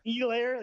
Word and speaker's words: Edwards-Helaire: He can't pronounce Edwards-Helaire name, like Edwards-Helaire. – Hilaire.